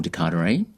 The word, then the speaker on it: De Carteret: So my Instagram is Joel De Carteret, pronounced Joel. Spelt Joel, but pronounced Joel. So J-O-E-L, De Carteret, D-E-C-A-R-T-E-R-E-T.